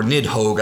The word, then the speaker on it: Nidhogg: Which is what I mentioned a little bit earlier, of the Nidhogg, or Nidhogg, I believe is the pronunciation, Norse pronunciation,